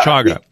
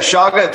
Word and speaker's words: chaga: But there is a new, what should we call it, preparation of shaga and coffee. Am I pronouncing that right? Shaga? – Yeah. – Chaga? – That's actually, yeah, shaga. Some people say it like that, and some people say chaga.